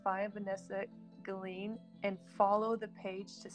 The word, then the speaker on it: Guillen: Today, I will tell you all about what happened to Vanessa Guillen. I always called it Guillen, but it's Guillen, which I did Google that, by the way, y'all.